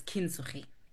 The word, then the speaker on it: Kintsugi: So Utandis into a therapist is through the art of Kintsugi or Kintsugi. Yeah, depending again with the pronunciation, but it's Kintsugi.